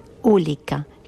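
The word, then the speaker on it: olika: La palabra diferente en sueco se dice ulika, que es el contrario de lika, que significa igual. Pero es que hay otra palabra que se pronuncia casi igual, pero que se escribe diferente, que es ulika, que significa accidente, y que es el contrario de lika, que significa felicidad.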